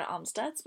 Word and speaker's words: Amstutz: My social media handles are all at Sandra Amstutz.